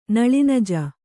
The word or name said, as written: ನಲಿನಜ